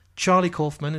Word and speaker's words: Charlie Kaufman: Charlie Kaufman, is that how you say it? – Yeah.